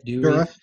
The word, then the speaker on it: Dourif: I'll probably say his last name wrong. Brad Dourif. Is that how you say it? – Dourif. Yeah.